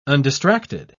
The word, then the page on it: undistracted: British English